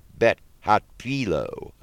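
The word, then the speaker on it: Beth Hatfiloh: This will be from 7 to 9 p.m., and it's at Temple Bet Hatpilo, I believe is how you say that, 201 8th Avenue Southeast.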